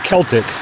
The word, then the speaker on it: Celtic: And one of the dudes jumped on me, and he goes, "It's pronounced Celtic." And I said, "No, you know, yeah, that's the basketball team. I guess that's one pronunciation." But I was like, "It's Celtic. I've only ever heard people say Celtic when they're referring to Celtic culture."